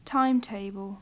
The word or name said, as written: timetable